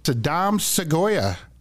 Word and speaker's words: Sadam Seguya: Calling in from Uganda, we have Sadam Seguya.